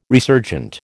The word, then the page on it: resurgent: American English